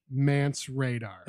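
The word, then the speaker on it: Mance Rayder: For sure, but it's pronounced Mance Radar.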